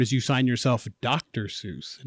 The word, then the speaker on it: Seuss: Seuss is actually pronounced Zeus in German, but Seuss said that he liked that people thought of it as Seuss because it put him close to Mother Goose. So when you're saying Seuss, you're saying it correctly, but the German pronunciation is actually Zeus.